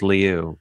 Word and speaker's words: Lieu: Congressman Ted Lieu, or Ted Lieu, as most people call him, but I went to high school with him. And I swear it's pronounced Lieu, but I have not talked to him in some time, but I need to confirm with him.